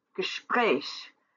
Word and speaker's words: Gespräch: Beruf, Gespräch both have two syllables, and the last syllable is accentuated.